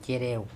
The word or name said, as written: cEreo